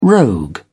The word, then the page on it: rogue: British English